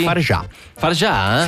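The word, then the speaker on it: Fargeat: Va bene signore e signori, parliamo di un film molto bello di Coralie Fargetta, com'è che si pronuncia qui? – Farjà? Sì, Coralie Farjà, The Substance, in onda sulla 1 venerdì alle 23:30.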